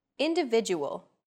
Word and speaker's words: individual: Individual. The stressed syllable vi in individual is deemed more important, so it's pronounced longer than all other syllables.